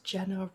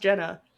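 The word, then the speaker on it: Gena: By the way, I thought it was kind of funny because Gina, it's spelled Gina. – Yeah. – Or how you would think Gina is spelled. Mm-hmm. But it's pronounced Jenna. And then it's written by Aaron Kruger, but spelled E-H-R-E-N, not how you think Aaron is spelled.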